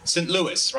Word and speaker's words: St. Louis: It's St. Louis, right? It's got an S at the end because I have a silent X at the end of mine, so it's always kind of confusing.